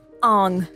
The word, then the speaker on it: Aang: His name is Ong. – Oh my god. – Spell that, I can't. – A-A-N-G. – Double A-N-G.